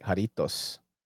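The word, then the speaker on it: Jarritos: So I was saying it right. Jaritos. Jaritos.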